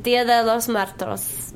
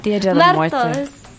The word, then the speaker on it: Día de los Muertos: Día de los Muertos. Oh, God. – Día de los Muertos.